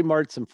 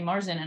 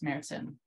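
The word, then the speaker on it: Märzen: I used to say Marzen, and I had a boss in Oregon that was like, you're going to pronounce it right if you're going to work in my beer bar. It's Martzen.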